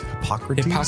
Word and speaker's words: Hippocrates: I believe it's Hippocrates.